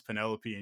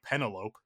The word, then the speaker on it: Penelope: One was Penelope, which if you don't know how to pronounce Penelope and you read it as a 8 or 9 year old, I was like Penelope. – Yeah, right. – I found out that it was Penelope.